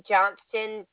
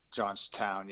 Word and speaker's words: Johnstown: Is that 108 Erie Street, Johnston, Pennsylvania, sir? – Johnstown.